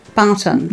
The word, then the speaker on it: BAATN: I know it's is it like the letters? It's Barton. – Barton. So it's BAATN, and it stands for Black African and Asian Therapy Network.